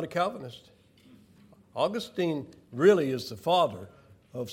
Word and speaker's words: Augustine: So Origen, but then Augustine. I was checking online. That's pronounced properly in two different ways. Augustine or Augustine.